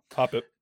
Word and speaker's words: Hoppip: So Hoppip. I think that's how you say his name. Hoppip. – Yep, Hoppip.